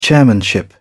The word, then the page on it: chairmanship: British English